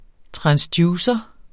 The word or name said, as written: transducer